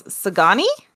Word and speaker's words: Sagani: He actually referred to the elementals collectively as Sagani. I don't know if I'm saying that right, but that's how I'm saying it. – You're definitely saying it right.